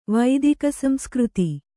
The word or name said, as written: ವೈದಿಕಸಂಸ್ಕøತಿ